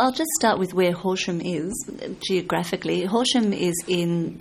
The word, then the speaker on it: Horsham: Horsham. – Horsham. – The English pronunciation, Horsham. That's right.